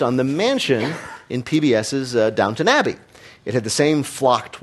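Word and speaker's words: Downton Abbey: Make it look like Downtown Abbey. – That's right. That's not how it's pronounced, though, Maz. You know that. – Not really the PBS crowd, apparently. No. Downtown Abbey.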